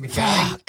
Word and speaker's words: McKay: It's spelt McKay, but it's actually pronounced McKay. But you know what, man? Don't worry, I've given up.